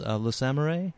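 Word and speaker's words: Le Samouraï: So we have Jean-Pierre Melville's Le Samurai. Do I say that right? I never know how to say it. – That's the way I would say it.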